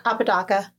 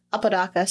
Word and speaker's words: Apodaca: So once he was tried for the murders of Carol Alford, Guadalupe Apodaca, I feel like I'm saying that so wrong. – Apodaca. – Apodaca. So I was saying it right.